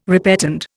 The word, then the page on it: repetend: Indian